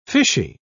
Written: fishy

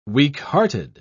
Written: weakhearted

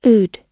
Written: oud

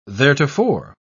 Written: theretofore

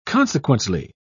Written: consequently

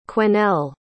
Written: quesnel

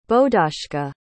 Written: bodashka

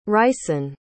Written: ryesen

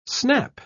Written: snap

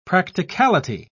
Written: practicality

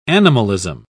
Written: animalism

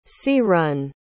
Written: sea-run

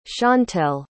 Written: shantel

